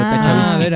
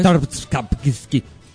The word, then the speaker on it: Tchaikovsky: Seguramente le hayan dicho así, pero no es así el apellido. Yo no lo sé pronunciar muy bien, por eso le digo Tachavisky. – A ver, ¿cómo es? – Tchaikovsky.